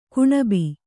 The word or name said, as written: ಕೊಣಬು